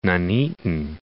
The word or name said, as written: Nuneaton